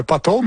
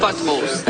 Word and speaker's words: pothole: Bravo, pothole, mais il faut bien prononcer le P. – Madame la mairesse est toujours parfaite. – Does that include buttholes in the sentence? Or? – Not buttholes. Potholes.